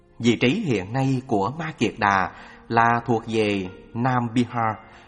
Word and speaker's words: Ma Kiệt Đà: Ma Kiệt Đà, phiên âm từ Phạn ngữ. Ma Kha Đờ Ha cũng đọc là Kiệt Xà hay Ma Già Đà,